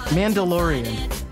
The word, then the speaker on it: Mandalorian: Wait, Milan? Why can't I say that? Mandalorian. I forgot how to say it for a second.